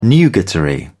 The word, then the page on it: nugatory: British English